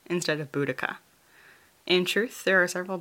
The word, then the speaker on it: Boudica: All that to say, I'll be going with Boudica, as that seems to be the accepted pronunciation as far as I can tell.